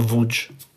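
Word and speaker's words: Łódź: From Lodz. From what? Or Wódź.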